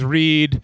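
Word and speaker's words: Reid: Like he he probably was like, "Let me show you how to pronounce his name is Reed," as in like the thing I don't know how to do with books.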